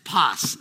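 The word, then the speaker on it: pas (πᾶς): It's pas. That's how it's pronounced.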